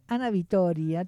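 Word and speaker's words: Anavitória: Ahora quienes cantan son Ana Vitoria, no lo pronuncié mal, ¿eh? Es Ana Vitoria, todo junto, y Victor Clay.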